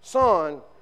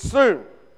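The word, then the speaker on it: soon (σύν): It's S-U-N, but you don't pronounce it son. It's soon.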